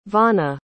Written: vonna

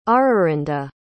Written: Ararinda

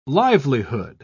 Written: livelihood

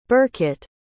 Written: burkett

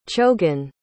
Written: chogan

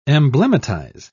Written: emblematize

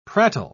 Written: prattle

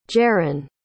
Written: jarran

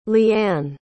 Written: leeann

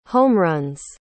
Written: homeruns

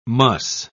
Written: muss

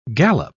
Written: galop

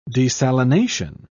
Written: desalination

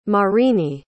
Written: Marini

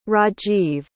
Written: Rajiv

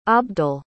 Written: abdel

